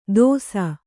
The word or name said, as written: ತೋಸ